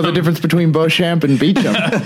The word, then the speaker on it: Beauchamp: But yeah, her name, any person with half a high school education who looked at that name on paper would pronounce it Beauchamp or Beauchamp. – Yes. – But she does say Beecham.